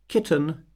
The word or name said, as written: kitten